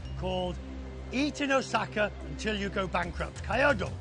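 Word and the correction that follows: Kui daore (食い倒れ): Kui daore.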